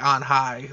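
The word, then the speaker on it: Anh Hai: It's A-N-H-H-A-I. – Oh, okay. – It's like On High. Like two different words.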